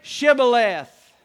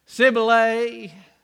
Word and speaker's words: Shibboleth: And they said, "Say Shibboleth." And they said, "Chevrolet." "Sibylay." They said, "No, you're an Ephraimite." And they killed him. That's what they did. Had a grammar problem. They couldn't pronounce Shibboleth.